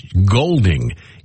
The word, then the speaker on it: Goulding: Ellie says she gets tired of people mispronouncing her last name. It's Goulding, even though it's spelled G-O-U-L-D-I-N-G.